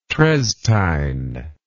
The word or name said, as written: treztine